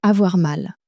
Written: avoir mal

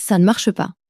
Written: ca ne marche pas

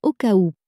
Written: au cas ou

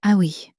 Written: ah oui

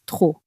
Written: trop

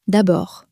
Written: d abord

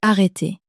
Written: arreter

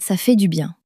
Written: ca fait du bien

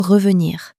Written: revenir

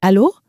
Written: allo

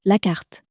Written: carte

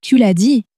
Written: tu l as dit

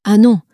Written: ah non

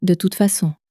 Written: de toute facon

